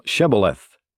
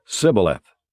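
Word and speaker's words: Shibboleth: If he said no, then they would say to him, "Say now Shebeleth," but he said Sibboleth, for he could not pronounce it correctly.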